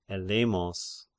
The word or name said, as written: Ellemos